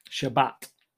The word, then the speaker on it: Shabbat: Happened to fall on the Shabbat. Again, is that pronounced right? Which is, you know, the Friday and Saturday. It's Friday after sundown. – Shabbat. – That's it.